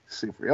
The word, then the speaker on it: Seyfried: How do you pronounce Amanda's last name? What's the Seyfried? – Seyfried. Okay. She kind of looks like if Michelle Pfeiffer and Kate Hudson had a baby, that's what she would pop out and be.